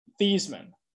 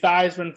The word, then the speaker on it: Theismann: So they said, hey, you should change your name so it's pronounced Theismann instead of Theismann. So you can say Theismann for Heisman.